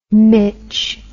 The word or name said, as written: mitch